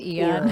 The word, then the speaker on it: Aeon: It starts with an A, but it's pronounced Ian.